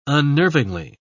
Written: unnervingly

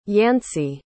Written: yancy

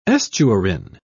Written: estuarine

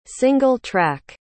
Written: single-track

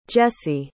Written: Jessie

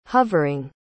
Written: hovering

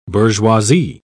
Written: bourgeoisie

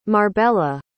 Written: marbella

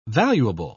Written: valuable

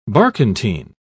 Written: barkentine

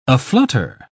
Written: aflutter

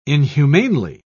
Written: inhumanely

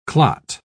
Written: clot

